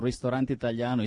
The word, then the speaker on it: Ristorante Italiano: No, I really appreciate to have you here, like in Tavolino, and your pronunciation on Ristorante Italiano is perfect.